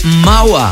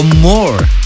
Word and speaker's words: Maur: Y venga, como tengo muchísima música y muchísimas cosas que contaros, sigo ahora con el que es el último trabajo de Mawa o More. Hay gente que lo pronuncia de una manera, otros de otra. Yo expongo las dos. Se escribe M-A-U-R.